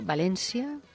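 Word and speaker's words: València: Y no lo acabo de entender, porque Unique, y luego Valencia, con el acento como Valencia, pronunciado así, ¿no? En valenciano. Pues se trata de proyectar Valencia, lo digo ahora en castellano. Esa es la diferencia, fíjate, la S y el acento.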